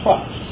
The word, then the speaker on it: trux: It is the word trux. Phonetically, it's spelled T-R-U-X, trux.